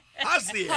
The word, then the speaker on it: Hosea: so H O S E A. – Hosea. Hosea. – Yeah, yeah. – Hosea. So you have to say all the syllables.